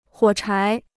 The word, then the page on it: 火柴: huǒ chái